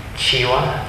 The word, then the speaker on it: Chiwa: Chiwa, C-H-I-W-A. La muerte.